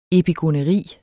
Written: epigoneri

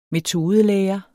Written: metodelære

